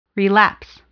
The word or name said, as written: relapse